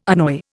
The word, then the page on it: annoy: Indian